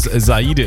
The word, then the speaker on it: Sahide: So wie der Speaker es teilweise ausgesprochen hat. Mittlerweile weiß auch der Speaker, dass es Sahide ist. Ja. Sahide ist mittlerweile 16 Jahre alt geworden, und zwar im Juni.